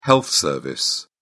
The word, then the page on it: health service: British English